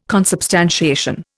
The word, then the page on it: consubstantiation: Indian